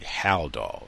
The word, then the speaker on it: Haldol: Haldol, I was saying it incorrectly. Haldol.